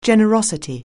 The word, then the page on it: generosity: anglais